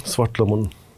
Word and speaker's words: Svartlamon: Danas imamo specijalnog gosta iz Norveške, muzičara i kompozitora Jovana Pavlovića, vođu norveškog punk hora Svartlamun, da? Da, da. Sam uspela ispravno da izgovorim. Potpuno ispravno, Svartlamun. Zdravo, Jovane.